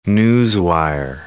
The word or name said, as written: newswire